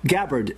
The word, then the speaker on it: Gabbard: Gabbard.